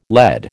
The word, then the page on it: LED: American English